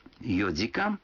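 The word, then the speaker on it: йодикан (四時間): Некоторые цифры в этих случаях произносятся не так, как обычно. 4 часа – это йодикан, 7 часов – ситидикан, а 9 часов – кудикан.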